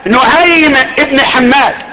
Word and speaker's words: نعيم بن حماد: نعيم ابن حماد هكذا النطق.